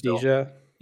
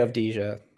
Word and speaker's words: Avdija: Avdija. – Yeah. How do you say it? – I say Avdija. – Avdija. – Just say it like it's spelled.